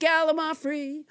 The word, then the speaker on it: gallimaufry: Let's never forget gallimaufry, which I pronounced wrong until nobody James Hyder corrected me.